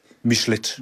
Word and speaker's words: Michelet: Michelet. – Ja. Det er et fransk navn, men de har fornorsket uttalen.